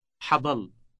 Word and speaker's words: حضل: حضل. حضل. – The initial part expressing future time is a sound similar to the H sound, but it is pronounced with more friction.